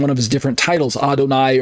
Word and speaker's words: Adonai: So on the right side of your screen, that's God, pronounced as Adon plus the word I, so Adonai.